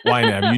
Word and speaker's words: YNAB: Number 33, use an online tool like PC, Mint, or YNAB. – Yes. – Is that how you say it? – Yep. YNAB. You need a budget.